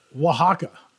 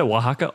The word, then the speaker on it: Oaxaca: Conceived in the mountains of Oaxaca. That's what mezcals are. Have you guys ever had mezcal? – Oaxaca, right? – Well, that's how you pronounce that. Oaxaca, O-A-X-A-K-C-A. Oaxaca.